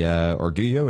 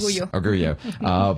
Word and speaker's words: Orgullo: Again, joint effort between El Centro and the town, and it's the Orgullo, is that how you pronounce it? – Yes. – Orgullo.